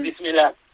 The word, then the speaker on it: Bismillah: cosa que me dijo Iria Sokomaz, a ver si la pronuncio bien, Bismillah. – Eso es. – ¿Lo he dicho bien? – Bismillah.